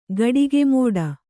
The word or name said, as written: ಗಡಿಗೆಮೋಡ